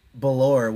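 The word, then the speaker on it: Bellore: But then Nick, and I don't know if his name is Nick, Belor, which rhymes with Bellator.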